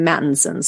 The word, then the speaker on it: Mattinson: So if you guys want to look me up, that's Leah, L-E-A-H-A. My name is spelled funny, so I'm easy to find. And last name, Mattinson. So M-A-T-T-I-N-S-O-N.